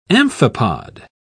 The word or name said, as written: amphipod